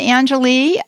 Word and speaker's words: Angel: Hi, no, it's Angel. – Angel. – You have to say the en and the je. – Angel. Oh, it's French. It's a Cajun pronunciation.